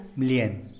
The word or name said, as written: blien